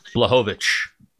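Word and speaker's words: Błachowicz: Like yo, he after after the Reyes, uh, was it Blanchovich? Is that how you say his name? – Blachowicz. Blachowicz. – Blachowicz.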